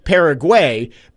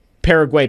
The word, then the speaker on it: Paraguay: And he says, He says it's Paraguay. He says his fiance is from there and had a come apart hearing me say that the other day. So I just looked up multiple videos, and some of them do say Paraguay, but then there's a video of locals saying it and they say Paraguay.